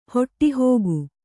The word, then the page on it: ಹೊಟ್ಟೆಹೋಗು: Kannada